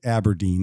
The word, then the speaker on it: abrdn: Aberdeen is abbreviated lowercase A-B-R-D-N, but you pronounce it Aberdeen.